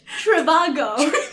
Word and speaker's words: Trivago: Hotel, Triviago. – Did you say Triviago? – Is it not pronounced? – Trivago. Hotel Trivago.